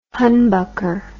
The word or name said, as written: humbucker